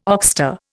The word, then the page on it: oxter: Indian